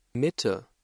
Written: Mitte

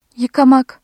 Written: yıka-

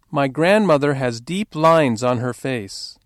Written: grandmother

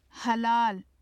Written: halal

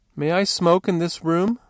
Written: smoke

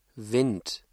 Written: Wind

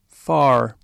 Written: far

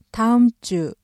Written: 다음 주

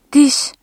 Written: diş

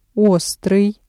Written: острый

